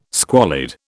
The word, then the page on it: squalid: British English